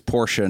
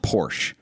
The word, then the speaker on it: Porsche: No, because we're not pronouncing Porsche with a German accent. We're just acknowledging this is two syllables. It's Porsche, not Porsche. It'd be like saying it's Ferrari, not Furry.